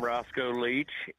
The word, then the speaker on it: Roscoe Leech: Hi, I'm Roscoe Leech, and I would say best talent agency either side of the Mississippi, if I might be so humble.